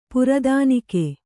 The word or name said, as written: ಪ್ರಧಾನಿಕೆ